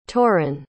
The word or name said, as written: toren